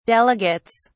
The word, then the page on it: delegates: British English